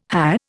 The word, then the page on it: hah: Indian